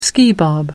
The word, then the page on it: skibob: American English